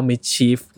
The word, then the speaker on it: MSCHF: m i ช c h i e f